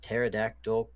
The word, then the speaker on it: pterodactyl: And finally, why can't you hear a pterodactyl going to the bathroom? – Because the pee is silent.